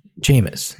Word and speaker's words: Jameis: Is pretty clear, but, and the latest, I mean, a lot's happened, but the latest is obviously the biggest, the test with, Jameson. No. How do you say his name? – Jameis, I think. – Jameis, not Jameson. Jameson's a drink.